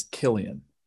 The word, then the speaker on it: Cillian: I've just found out that the way to pronounce his name is Killian.